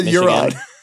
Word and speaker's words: Huron: How do you pronounce that first one again? – Huron? Is it? How do you spell it? Yeah, you say Huron. – Huron?